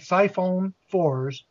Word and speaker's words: siphonophores: Because it looked like siphonophores.